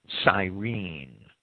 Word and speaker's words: Cyrene: Many commentators have asserted that this Simon was some sort of brown Arab or even a Negro simply because he was from Cyrene. Cyrene is, I think it's usually pronounced Cyrene. It's spelled Cyrene in English. Cyrene, K-U-R-E-N-E, would be the Greek pronunciation.